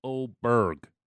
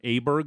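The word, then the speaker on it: Åberg: So now apparently it's Oberg. – Oberg? – Yeah. – Pontus Oberg? – Okay, yeah, fine. – I think that's what he wants to be called. It's a hard G. – Oberg? – Yeah. – Right. – What was it before? – Because we were all Aberg was wrong. And he was like, you're all pronouncing it wrong.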